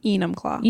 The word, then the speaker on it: Enumclaw: Enumclaw? – Enumclaw.